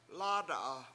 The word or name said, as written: lAAda7a